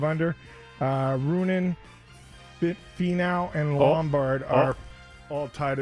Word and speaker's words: Finau: Well, it's hard because it's when you say it, it's Finau. – Uh huh. – But it's spelled Finau. So Finau.